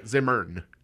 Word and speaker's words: Zimmern: But you look at Andrew Zimmer. – Yeah, Zimmern. – Zimmern.